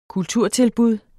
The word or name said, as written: kulturtilbud